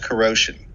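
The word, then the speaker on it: Kuro5hin: And around the year 2000, I started writing for a website called Corrosion, spelled K-U-R-O, digit 5, H-I-M. – Okay. – Meant to be pronounced like the word corrosion because the site's founder was named Rusty Foster.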